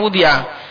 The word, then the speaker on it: wudi'a: Allati wada'a, allati wudi'a, bacanya wudi'a.